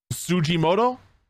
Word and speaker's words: Tsujimoto: The actual quotes that were said by the Capcom chief operating officer Tsujimoto, I think that's how it's pronounced. T-S-U-J-I-M-O-T-O.